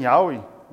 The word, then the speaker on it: Yahweh: Aquele Senhor que o Jeová, né, que não é a tradução correta, é Yahweh, né, que se pronuncia até assim, Yahweh, mas isso é uma longa discussão.